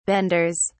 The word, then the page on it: benders: British English